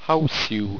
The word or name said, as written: houisew